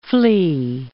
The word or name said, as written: flea